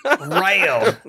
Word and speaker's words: Royale: What did you just pronounce it like? Royale? – Rail. – Rail. That sounds like a sword. – Australia, mate. Battle Royale.